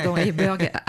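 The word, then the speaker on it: IJburg: Concernant ces maisons flottantes d'Eiburg, c'est comme ça qu'on prononce Eiburg. – Eiburg, aux Pays-Bas.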